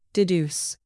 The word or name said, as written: deduce